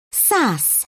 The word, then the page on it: sass: British English